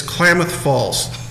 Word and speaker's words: Klamath Falls: Is it pronounced Klamath Falls or Klamath Falls? It's Klamath Falls.